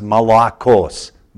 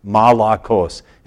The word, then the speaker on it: malakos: The word here in the original Greek is a word that's pronounced malakos, malakos, and it means softness.